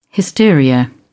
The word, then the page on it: hysteria: British English